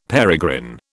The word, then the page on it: peregrine: British English